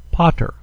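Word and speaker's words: pater (πατήρ): The first word is pater. It is the word for father, and it's split between the alpha and the tau, because the tau goes with the epsilon that follows it. Pater, father.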